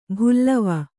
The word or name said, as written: ಭುಲ್ಲವ